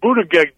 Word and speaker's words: Buttigieg: No, look at Buttigieg there is ahead of everybody right now.